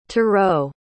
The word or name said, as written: tarou